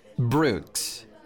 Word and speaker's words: Brooks: O-L-I-V-E-R. Oliver. – What is your last name? – Brooks. B-R-O-O-K-S.